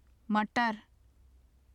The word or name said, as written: matar